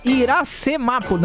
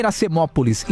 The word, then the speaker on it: Iracemápolis: Ó, o cara falando aqui, ó, é Iracemápolis, né? Não é Iracemópolis. Iracemápolis. Agora falei certo.